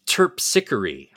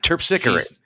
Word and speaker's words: Terpsichore: It's Terpsichore. – Terpsichore.